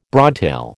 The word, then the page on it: broadtail: American English